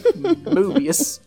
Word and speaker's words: Moebius: By the way, that's spelled M-O-E-B-I-U-S. Mobius.